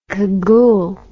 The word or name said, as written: kagoule